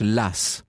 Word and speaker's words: Law: Alors ça s'écrit Law comme loi en anglais, L-A-W, mais la tradition veut qu'on prononce Lass.